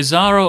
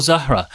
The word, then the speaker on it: Zahra: So Zara or Zahra. Yeah. And different audiences say different things. And of course, how she actually pronounces her own name. – We don't know. – You know, so some people call her, she'll probably call herself, anglicize herself to Zara.